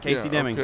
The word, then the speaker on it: Casey Deming: Yep, Casey Deming.